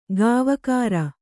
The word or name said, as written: ಕೋವಿಗಾರ